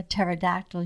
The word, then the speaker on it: pterodactyl: Why can't you hear a pterodactyl using the bathroom? – Because the P is silent.